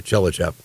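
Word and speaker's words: Tchelistcheff: That's Chelistchef. Two F's at the end. Aren't you glad I actually pronounced that one for you? Chelichev.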